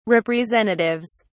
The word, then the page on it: representatives: British English